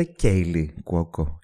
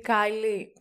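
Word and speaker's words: Kaley Cuoco: Ε, το όνομα τη προφέρεται Κέιλι Κουόκο. Κέιλι. – Νομίζω ναι, αλλά δεν είμαι σίγουρη. – Νιώθω ότι θα πρέπει να έχει ένα Y. – Κέιλι, Κάιλι. Κάιλι αποκλείεται.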